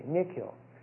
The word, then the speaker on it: Nikhil: N-I-K-H-I-L. – Okay, Nick Hill.